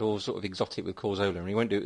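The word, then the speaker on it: Cazorla: Jonathan Pearce and his pronunciation of Carzola. You know, he pronounces the th in Carzola instead of the z, trying to sound all poncy and foreign and hipster.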